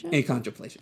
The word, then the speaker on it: achondroplasia: How do you say that? Achondroplasia. Okay. – You pronounced it correctly.